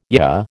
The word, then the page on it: yah: American English